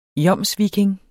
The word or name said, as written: jomsviking